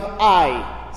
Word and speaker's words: Ai: You've got the city of Ai, spelled A-I. Strange spelling as well. But as far as I know, that's how it's spelled. That's how it's pronounced.